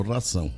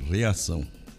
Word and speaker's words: reação: Você pronunciou ração, é reação.